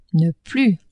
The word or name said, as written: ne-plus